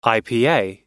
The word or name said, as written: ipa